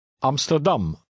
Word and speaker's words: Amsterdam: In the following names of towns, stress the last syllable. – Lees nummer 10 hardop. – Amsterdam.